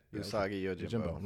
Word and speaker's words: Usagi Yojimbo: And we also going to talk about the announcement of Netflix having, I'm not going to say it right, Usagi Yojimbo? – Usagi Yojimbo.